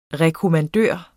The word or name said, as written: rekommandør